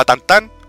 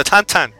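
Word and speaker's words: Tintin: ¿Tú sabes que no se dice Tintín? Se llama Tantán. – ¿Qué? – ¡Jaleo! – ¿Qué? – ¿Sí? – ¿Qué? – Tintín se llama Tantán. Se pronuncia Tantán.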